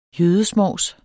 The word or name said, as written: jødesmovs